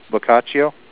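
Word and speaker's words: bocaccio: Boccaccio. – 19 Boccaccio, and then we had the Coraloma go out with 36 anglers, 213 rockfish, 42 vermilion, 3 whitefish, and 60 sand dabs.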